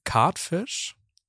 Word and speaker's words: Cartfish: Ist von einem DJ und zwar Cardfish. Mhm. Das spricht man mit Sicherheit Nein. – Englisch aus. – Catfish, ja, kenne ich. – Sicher? – C-A-T, oder? – C-A-R-T-F-I-S-H.